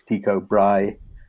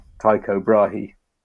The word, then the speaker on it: Tycho Brahe: I remember I had an amazing physics teacher, Mr. Dixon, and I really strongly remember him telling me about Tycho Brahe, which I'm now reliably informed by a Danish member of an audience when I was talking about the stories is how we pronounce Tycho Brahe. I was saying Tycho Brahe, but apparently that's not right.